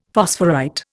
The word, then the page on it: phosphorite: Indian